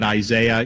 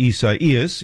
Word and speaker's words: Isaias: So, you know, I'm calling it Isaiah. You guys can call it what you want. I know that's not how you pronounce this.